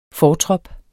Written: FORTROP

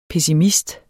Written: PESSIMISTEN

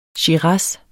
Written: shiraz

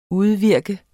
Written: udvirke

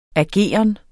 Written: ageren

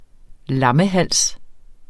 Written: lammehals

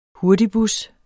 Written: hurtigbus

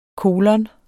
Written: kolon

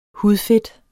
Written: hudfedt

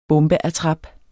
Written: bombeattrap

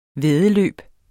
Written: væddeløb

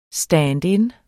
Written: stand-in

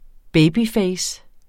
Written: babyface

